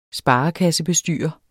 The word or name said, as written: sparekassebestyrer